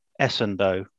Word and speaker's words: Essandoh: So as this podcast is uploaded, it's been approximately 20 years to the day, let's say, since Roy Essandoh hit the headlines. Sorry, is it Essandoh or Essandoh? – Well, to tell you the truth, I prefer Essandoh. Pretty sure that's correct.